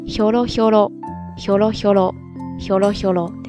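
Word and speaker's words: ほろほろ: 只是这个时候的声调呢，要读作 holo holo holo holo holo holo ですね。比方说 holo holo na hito holo holo na hito，就是那种又瘦又高，而且关键是让人感觉到弱不禁风的那种人。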